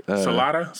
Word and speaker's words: Salida: Oh, like Salida, Salida, S-A-L-I-D-A.